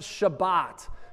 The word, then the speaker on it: Shabbat: It's a Hebrew word pronounced Shabbat.